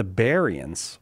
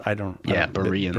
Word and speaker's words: Bereans: And it's the one where the writer of Acts of the Apostles, St. Luke, tells us that the Bereans were, or some people say Bereans, Bereans. – Bereans is maybe the preferred pronunciation, were more noble for examining the scripture daily to see if what Paul taught was true.